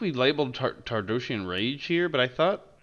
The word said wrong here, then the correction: Trandoshan: I kind of prefer the wait, what'd you call it? – Trandoshan Rage. – Okay. – Did I say it wrong? – You I did say something different.